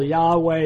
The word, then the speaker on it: Yahweh: Yahweh is it.